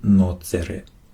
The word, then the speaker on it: nocere: Primum non nocere, vor allem nicht Schaden. Nocere, genau, das C wird hier so ausgesprochen wie ein K auf Latein.